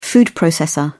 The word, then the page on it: food processor: British English